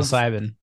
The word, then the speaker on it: psilocybin: Microdosing of psychedelic substances such as LSD or I may be saying this wrong. – Psilocybin. – There we go.